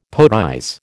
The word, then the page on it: poetize: American English